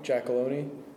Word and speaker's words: Giacalone: My name is John Giacalone. It's spelled J-O-H-N. Last name is G-I-A-C-A-L-O-N-E.